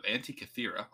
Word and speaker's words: Antikythera: But at some point, a Greek ship sank off the coast of the island of Antikythera. I think I said that right, but if I did not... – Sounds right to me.